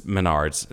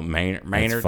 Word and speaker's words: Menards: Yeah, M-E-N-A-R-D-S, but okay. it's Menards. It's pronounced Menards, but they call it Maynards.